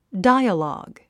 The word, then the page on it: dialogue: dǽiəlɔ̀ːɡ; ダイアログ